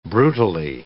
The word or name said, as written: brutally